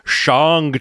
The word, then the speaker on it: Shang-Chi: Like, they've now got a guy coming in saying, Shang-Chi, the Legend of the Ten Rings. It's like they want to make sure we all know, because I think everyone in the world was saying it wrong, or at least everyone I heard said it wrong for like a year and a half.